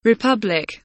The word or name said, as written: republic